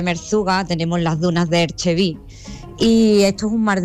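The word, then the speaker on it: Erg Chebbi: Ajá, Erchevi se pronuncia. Erchevi.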